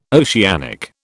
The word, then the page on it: oceanic: British English